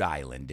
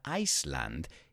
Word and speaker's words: Island: It's 1952, and this was founded in Rhode Island in the United States. Careful with the pronunciation there. It's not Iceland, it's Island. And this was the beginning of festival culture, you could say, la cultura de festival, in the Western world, el mundo occidental.